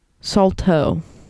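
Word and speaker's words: Saulteaux: Now, I want to fact check, yes, Salto is how you say it.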